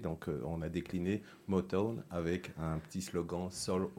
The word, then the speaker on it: Motown: Toi, ta société aujourd'hui, elle s'appelle Moton. – Motown, prononce bien, s'il te plaît. Motown Oui, presque. Ah, vas-y, redis-nous. Motown. Motown. – M-O-T-O-W-N, inspiré du fameux label euh, musical Motown.